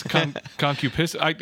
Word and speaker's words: concupiscence: I'm putting the emphasis on the wrong syllable. – I was going to say, I've never heard it said. – Concupiscence. – Yeah. I always. You got to be careful how you say it. – I like that. – Yeah. – It's concupiscence.